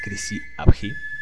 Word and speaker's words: Chrysi Avgi: Así que bueno, ¿Qué es Crisi Abhi. Eh, al principio del podcast lo pronuncié mal, pero según tengo entendido, así se pronuncia Crisi abji Es un partido político griego con una ideología filonazi, o sea, sí que es simpatizante con, algunos aspectos del nacionalsocialismo.